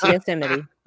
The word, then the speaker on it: Yosemite: We have Redwood, Joshua Tree, Death Valley, uh, Yosemite or Yosemite, as some people might pronounce it. – it's Yosemite.